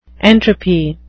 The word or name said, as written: entropy